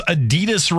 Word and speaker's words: Adidas: Number five, Americans pronounce Adidas wrong. – Uh-oh. – It's actually pronounced Adidas. – Adidas. – With a slight emphasis on the first syllable, not Adidas.